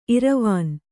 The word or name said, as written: ಇರವಾನ್